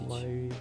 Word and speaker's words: Maui: Мауи, да. – M-A-U-I? – Ну, что такое, да.